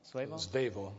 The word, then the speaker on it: Svevo: Start dealing with the, you know, I don't know if I'm pronouncing his right name right, but Italo Svevo? – Svevo.